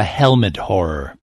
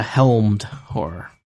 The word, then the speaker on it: helmed horror: Now, is this a helmed horror or a helmet horror? It makes a big difference if that extra syllable is pronounced. – It's a helmed horror.